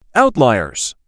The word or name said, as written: Outliers